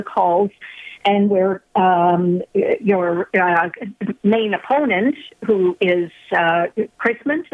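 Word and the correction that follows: Keesmaat: is that how you pronounce her name? Kiesmat. – Yes, that's right, yeah.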